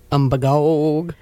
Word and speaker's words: Umbagog: How do you pronounce it? – Umbagog. – Umbagog. Do you know that the wardens around here, they all say, and the fishing guides and people, they all say it's Umbagog.